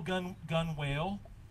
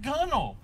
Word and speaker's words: gunwale: How do y'all spell gun whale? Right, when you read that word, G-U-N-W-A-L-E, that's gunnel. – But you pronounce it gunnel. I'm a landlocked Tennessean. So last week I said gun whale when you actually pronounce it gunnel.